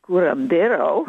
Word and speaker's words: curandero: He is a respected Camasca curandero, if I say that right, from Peru.